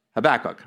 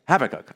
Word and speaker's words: Habakkuk: In England, we say it Habakkuk, right. And how do we say it in America? Habakkuk.